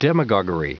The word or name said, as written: demagoguery